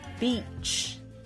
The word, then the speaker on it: beach: beach e 长元音，一个是 bitch 短元音，诶 Hope you enjoyed today's video。